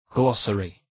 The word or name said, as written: glossary